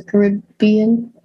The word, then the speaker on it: Caribbean: Ooh, I love Pirates of the Caribbean. – I know. You got to say it Caribbean because that's how they say it.